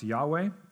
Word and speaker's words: Yahweh: Then the Lord, and notice it's capital L-O-R-D, and so behind that is the tetragrammaton. It's the Hebrew name of God, which we might pronounce Yahweh, appeared to Abram and said, to your offspring I will give this land.